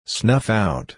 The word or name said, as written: snuff out